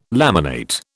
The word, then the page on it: laminate: British English